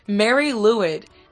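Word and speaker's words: Mari Lwyd: Yeah, Mary Lewid. So, if you want to look it up yourself, Tyler, it's M-A-R-I space L-W-Y-D.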